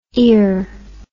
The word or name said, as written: ior